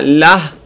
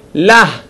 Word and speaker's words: לה: ולפעמים ה מתבטאת, כמו המילה לה, מבטאים את ה. איך אני יכול להבחין? זה העבודה של המפיק. זהו התפקיד של המפיק. המפיק בה אומר לי, תבטא ה. תגיד לה. תוציא את ה מתוך הגרון.